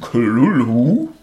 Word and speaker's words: Cthulhu: Das heißt, dieses H in, in Klullu, das spricht man gar nicht. Verrückt, oder? Sozusagen. – Ein stummes H. Wollen wir jetzt die ganze Folge Klulhu sagen? – Oder wollen wir darauf verzichten?